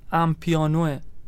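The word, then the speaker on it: Amapiano: اسمش تلفظش درست امپیانوه. – اوکی. – اینه اگه شما میگم تلفظ درستش اینه.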